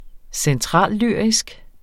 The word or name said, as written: centrallyrisk